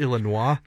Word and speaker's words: Illinois: It's pronounced Illinois.